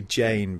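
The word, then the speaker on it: Jeyne: It's actually I've been calling her Jean for ages, but I think it's supposed to be Jane, because there's a little passage where Theon's in his, you know, he's sort of crazy thinking to himself. He says, yeah. Jane rhymes with pain, which is pretty cool.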